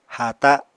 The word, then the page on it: haata: Apatani